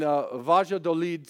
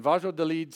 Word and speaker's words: Valladolid: In Valladolid, Spain, I learned how to pronounce that from Pastor Jose, by the way, who is from Spain. In Valladolid, Spain, it's where Christopher Columbus died, by the way, in 1506, in this place, in this town in Spain.